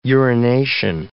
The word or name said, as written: urination